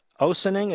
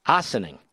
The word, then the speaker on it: Ossining: Nothing stops the assessors for Westchester County or the town of Ossining. Is that how you pronounce it? Ossining, excuse me.